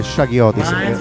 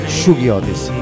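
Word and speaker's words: Shuggie Otis: Everywhere I look, a Shoggy Otis, noi siamo sempre stati abituati a chiamarle Shaggy Otis, chiaramente stiamo parlando del figlio di Johnny Otis.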